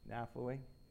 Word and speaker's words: Naphtali: Naphtali? – Okay, Naphtali, there you go.